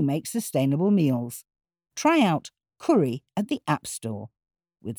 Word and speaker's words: Kuri: It's called Curry. Spell it Curry. K-U-R-I for iPhone and iPad.